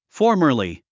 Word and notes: formerly: fɔ’ː r mə r li; フォーマリィ

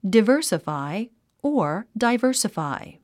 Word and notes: diversify: divə́ː r səfài; ディバーサファイ

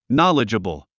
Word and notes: knowledgeable: nάlidʒəbl; ナリジャブル

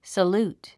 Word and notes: salute: səlúːt; サルート

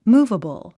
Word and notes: movable: múːvəbl; ムーバボォ

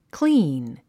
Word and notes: clean: klíːn; クリーン

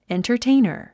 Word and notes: entertainer: èntərtéinər; エンタァテイナァ

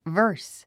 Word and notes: verse: və́ː r s; バース

